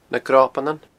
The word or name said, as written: nagnobanan